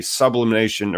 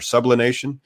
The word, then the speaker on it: sublimation: Maybe, you know, if they're stocking a sublimation or sublination, how do I pronounce it? – Sublimation, yes.